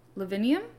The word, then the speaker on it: Lavinium: According to tradition, worship of Vesta in Italy began in Lavinium.